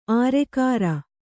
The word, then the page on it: ಆರೆಕಾರ: ārekāra